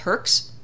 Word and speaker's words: Herx: This is a long process, but I became extremely ill with a thing called Herx. Is that correct, Virginia? Am I pronouncing it? – Yeah, not herpes. Not like herpes.